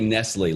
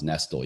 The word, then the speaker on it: Nestle: It looks like Nestle, like the candy company Nestle, but it's pronounced Nestle.